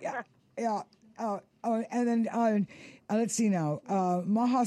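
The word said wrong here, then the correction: Maja: Stark. I think, is that pronounced correct, Maha? – Maya Stark.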